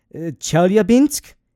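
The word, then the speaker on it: Chelyabinsk (Челябинск): So what happened in 2013 is that there was another airburst above, this is me trying to pronounce Russian, Chelyabinsk. in the Ural district of Russia. – That sounds very Russian, so it sounds correct to me.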